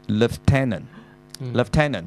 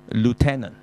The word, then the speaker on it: lieutenant: 咁佢呢個咧其實有兩個讀音嘅，又係有一個咧就係叫做 lieutenant 就係中尉，但係亦都咧好似美國呢、這個好似係美國嘅美國定邊個美國英國我都唔記得咗，就咁讀 lieutenant。即係變咗個 I 字係唔發音啊，差唔多係，就或者個 E 字都冇埋啦，LU 咁上下嘅啫，就係 lieutenant。咁但係亦都有人讀 lieutenant 嘅，兩個都得。